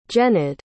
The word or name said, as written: jenyd